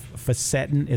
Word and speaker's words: Fisetin: I had never come across that word before, so different ways to pronounce it. Fisetin is how I'm pronouncing it.